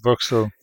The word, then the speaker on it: Boxtel: Yeah, Boxtel.